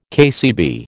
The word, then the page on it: KCB: American English